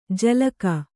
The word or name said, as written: ಜಲಕ